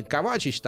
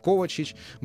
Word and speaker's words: Ковачич: Естественно, Лука Модрич, Иван Ракитич, как и известны хорватские имена, э, вернее, имена и фамилии, да, здесь, как правило, ударение на первый слог, поэтому не Ракитич, не Ковачич, там, не Брозович или там э, Манжукич, а Ракитич, Кова... Ковачич, Брозович и Манжукич.